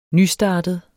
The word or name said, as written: nystartet